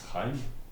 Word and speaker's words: Chaim: It's Chaim. Well, I'm it's like that. Chaim? Chaim? – The CH is just a hard H.